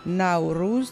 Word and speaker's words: نوروز: در کشورهای مختلف تلفظ نوروز متفاوت است. در شهر لنکو هند که هر سال نوروز برگزار می شود و هفته نامه هم با نام نوروز دارد، آن را به صورت نوروز تلفظ می کنند که به تلفظ زبان دری نزدیک است.